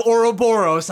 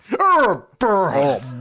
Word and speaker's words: Ouroboros: But that was the whole idea of Ouroboros. I'm sorry. Continue. – No, you said it wrong. Ouroboros.